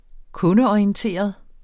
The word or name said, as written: kundeorienteret